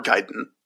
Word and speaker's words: Gaiden: Gaiden, or Gaiden, I guess, is probably how that is properly pronounced.